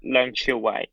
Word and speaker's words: Leung Chiu-Wai: So it's Leung Chiu-Wai. – Leung Chiu-Wai, yes.